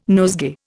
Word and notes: nosegay: Indian

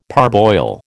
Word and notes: parboil: American English

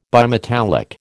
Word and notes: bimetallic: American English

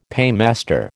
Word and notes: paymaster: American English

